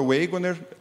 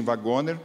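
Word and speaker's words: Waggoner: Wagoner, brasileirando, alguns dizem Wagoner, mas se pronuncia Wagoner.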